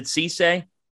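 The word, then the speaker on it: Cissé: Is it Cisse? – Yes.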